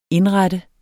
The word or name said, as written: indrette